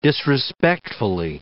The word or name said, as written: disrespectfully